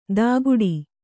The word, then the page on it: ತಾಗುಡಿ: tāguḍi